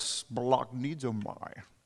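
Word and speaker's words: splagnidomai (σπλαγχνίζομαι): The Greek word for that is this word splagnidomai. And I say it like that because it comes from the word splognon.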